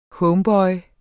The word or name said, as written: homeboy